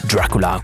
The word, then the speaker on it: Dracula: bisogna pronunciarlo così, Dracula, come lo dicono loro, a 4 minuti dalle ore 4 del pomeriggio.